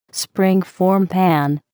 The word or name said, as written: springform pan